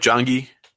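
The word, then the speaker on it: Janggi: Um, next up is twelve Jongi. Did I say that right? Jongi. – Yeah, I believe that's correct.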